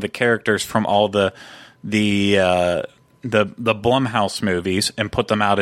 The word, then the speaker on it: Blumhouse: No, but then we're waiting on the, which I will now pronounce it correctly, because apparently I didn't last week, Blumhouse, not Bloomhouse, yeah, as I was saying before. Blumhouse, we're still waiting on that.